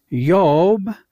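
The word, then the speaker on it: Yob (איוב): And in the Hebrew, it's pronounced Yob.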